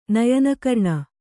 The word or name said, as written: ನಯನಕರ್ಣ